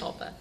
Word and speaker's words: sankalpa: Think of something positive, a samskara. Am I saying that right? – No. When do we say? – Sankalpa, thank you.